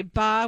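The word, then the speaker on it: ba (八): Because in Mandarin, you say ba, which is 8, which rhymes with fa, which means to make money.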